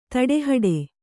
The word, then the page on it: ತಡೆಹಿಡಿ: taḍe hiḍi